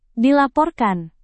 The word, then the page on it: dilaporkan: indonesian